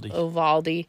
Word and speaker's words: Uvalde: And I find what's really interesting is today I read a meme on the whole Texas shooting in, what is it, Uvalde? How do you say it? – Uvalde.